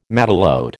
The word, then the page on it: matelote: American English